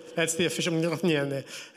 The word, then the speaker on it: Mjolnir: So Thor's power, he yields this hammer called, no one actually knows how to pronounce it. It's Mjolnir. That's the official Mjolnir.